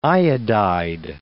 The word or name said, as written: iodide